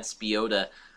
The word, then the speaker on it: Spiotta: It's actually Spiotta. But, Spiotta. Spiotta. Like Piotta, the restaurant.